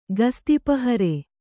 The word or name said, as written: ಗಸ್ತಿಪಹರೆ